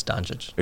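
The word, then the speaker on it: Dončić: No, I think the N is silent, isn't it? – No, it's Doncic.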